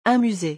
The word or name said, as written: Un musee